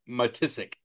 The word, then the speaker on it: Matisic: Yes, the TCU long snapper Brent Matisic is how you say it.